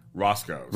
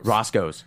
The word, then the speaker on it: Roscoe's: I hope I don't pronounce this wrong. Rocco's? – Roscoe's. – Roscoe's.